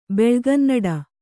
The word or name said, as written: ಬೆಳುಗನ್ನಡ